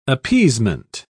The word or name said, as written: appeasement